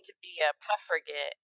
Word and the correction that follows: suffragette: And did I pronounce that correctly? – Yeah. Okay. So, suffragette.